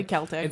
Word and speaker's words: Celtic: I will say that it's Celtic. I don't want to hear anybody saying Celtic. – Definitely Celtic. – It's pronounced Celtic.